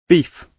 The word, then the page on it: beef: British English